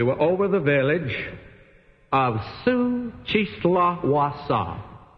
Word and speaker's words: Xochitlahuaca: And if you don't believe that that's the way to pronounce it, let me spell it for you. Xochitlahuaca. So you can see I pronounced it accurately.